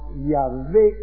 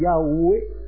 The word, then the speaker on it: Yahvé: Ya sabéis que lo más probable es que no sea Jehová, sino Yahvé, según los asquenazis, y Yahweh, según los sefardíes.